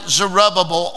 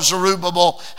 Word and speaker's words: Zerubbabel: that it is led by Zerubbabel, or Zerubbabel, however you prefer to pronounce it. Both pronunciations are correct.